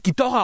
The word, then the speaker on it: Ghidorah: Also das ist der Typ, der heutzutage irgendwo in irgendwelchen Internetforen abhängen würde, wenn du dann halt irgendwie so sagst, ja, hier Ghidorah, der wird Ghidorah ausgesprochen. Entschuldigung.